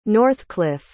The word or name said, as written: Northclif